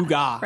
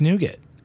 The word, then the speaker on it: nougat: Wait, what are the other ways to pronounce nougat? – Well, some people say nougat, and some people say nougat, and some people... I did see somebody, Russell, nougat.